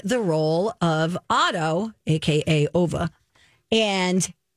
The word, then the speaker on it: Ove: And I went, wait a second, is this based on the book that I just finished called A Man Called, some people might think it's pronounced Ove, but it's Ova. – Okay. – A Man Called Ova, and because it's spelled O-V-E.